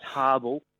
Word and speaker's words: Habel: It is pronounced, it's Habel. Habel.